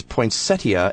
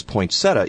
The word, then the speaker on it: poinsettia: And she found this and wrote to me, according to the Oxford English Dictionary, the I is silent in American pronunciation. It is no more incorrect to pronounce poinsettia as poinsettia in the United States than it is to pronounce Worcester as Worcester in England.